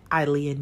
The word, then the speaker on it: Ilia: You can keep up with me at my personal page at Aaliyah Nico. That's spelled I-L-I-A.